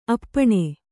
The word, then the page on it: ಅಪ್ಪಣೆ: appaṇe